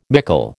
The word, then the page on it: mickle: American English